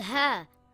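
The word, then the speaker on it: Ha (ه): Ha is a light letter and should not be pronounced heavy. Ha makes a similar sound in English to the letter H in the word hat or the word horse.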